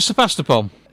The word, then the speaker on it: Sevastopol: So, Sevastopol, is that anywhere near the correct pronunciation? – Oh, that's exactly right.